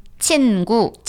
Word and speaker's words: 친구: 친구. – 친구. – 네, 친구죠. – 친구. 친구. – 친구. – When you pronounce it quickly, it mm-hmm. sounds like 친구.